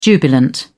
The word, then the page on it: jubilant: British English